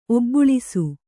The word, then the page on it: ಒಬ್ಬುಳಿಸು: Kannada